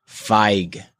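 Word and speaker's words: Fieg: Feig is actually pronounced Feig. – Yeah. – Ronnie Feig. – He would know that. – Yeah. – He spelled it phonetically. He said F E Y E G. – Yeah. – Feig. – Feig. – Like an I.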